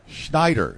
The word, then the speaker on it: Scheneider: Jess Scheneider.